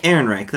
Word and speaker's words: Ehrenreich: Alden Ehrenreich? – Ehrenreich, that's it.